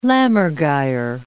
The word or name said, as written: lammergeier